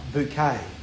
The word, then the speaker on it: Bucket: But the whole thing of the program is her name is spelt Bucket. But she'd say Bouquet.